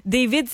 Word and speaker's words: D4VD: David, ça s'écrit D4VD.